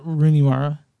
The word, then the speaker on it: Rooney Mara: Rooney Maria. Am I saying that right? Rooney Maria? – Rooney Mara.